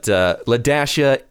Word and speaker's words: Laiah: But Ladashia is the correct way of pronouncing L-A-I-A-H.